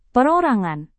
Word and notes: perorangan: Indonesian

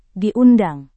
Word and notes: diundang: Indonesian

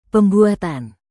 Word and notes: pembuatan: Indonesian

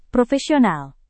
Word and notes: profesional: Indonesian

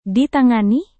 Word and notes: ditangani: Indonesian

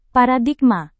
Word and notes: paradigma: Indonesian

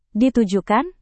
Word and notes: ditujukan: Indonesian

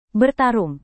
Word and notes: bertarung: Indonesian

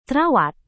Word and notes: terawat: indonesian

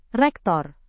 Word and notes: rektor: indonesian

